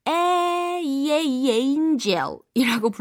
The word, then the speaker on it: angel: 중간에 angel 이라는 발음을 우리가 연습하도록 엔젤이 아니라 에이에이에인젤이라고 불러주는 부분도 기억이 나실 거예요. 그쵸?